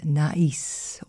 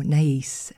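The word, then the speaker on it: Naïs: Starting this middle hour of Daybreak is an opera by Rameau called Nais or Nais. It's spelt N-A-I-S.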